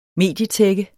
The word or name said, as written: medietække